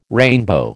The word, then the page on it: rainbow: American English